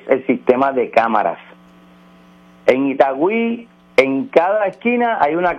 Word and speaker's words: Itagüí: Itagüí, sí.